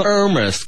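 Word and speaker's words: Hermès: Hugo 嘅，系啊，因为佢哋系嗰个 H 系发音噶嘛，就系法文系 H 唔发音噶嘛。 – 哦，咁样样。 – 系啊，就爱马仕噶嘛。 – 即系唔发音嘅 H 啦。 – H 唔发音噶嘛。 – 对。 – 啊，唔系 Hermes 噶嘛。系 Hermes 噶嘛，系咪先？ – 系。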